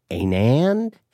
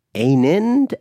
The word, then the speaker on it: Anand: So I said, rhymes with Amand. Anand? Rhymes with Amand. Anind?